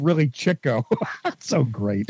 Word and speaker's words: Chico: Well, actually, it's pronounced Chico because he was always after the chicks.